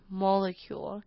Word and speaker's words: molecule: 下一个要来看看的字是 molecule。 Molecule，它其实也是标准的，看到它的单字会不太知道要怎么念的。 Molecule，M-O 的 O 呢是发 R 的音，L-E 发 Molecule，C-U-L-E 是 cule 的音，Molecule。